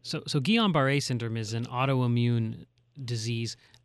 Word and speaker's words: Guillain-Barré: And now they are, and I'm going to mispronounce this, I'm sorry, Guillain-Barré. – That's not about right? – Guillain-Barré syndrome.